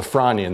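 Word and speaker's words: Frasnian: what is in this time period known as the Fronian, the S is silent, of 382 or so million years old.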